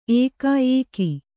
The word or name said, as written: ಏಕಾ ಏಕಿ